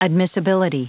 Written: admissibility

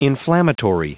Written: inflammatory

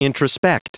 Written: introspect

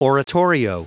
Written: oratorio

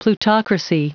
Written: plutocracy

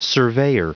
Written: surveyor

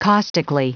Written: caustically

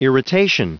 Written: irritation

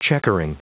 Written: checkering

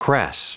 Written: crass

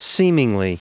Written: seemingly

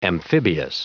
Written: amphibious

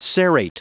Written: serrate

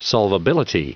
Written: solvability

